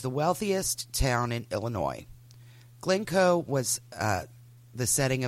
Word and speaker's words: Illinois: She grew up in the neighboring communities of Highland Park and Glencoe, Illinois. Illinois. Um, if you're not from, you know, the S is silent.